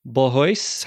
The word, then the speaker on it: Bolhuis: And today we've got Nick Bolhuis.